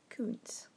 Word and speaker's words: Koontz: This book was What the Night Knows by Dean Koontz, and that's spelled K-O-O-N-T-Z, since it's a bit of a strange spelling, so I thought I should tell you.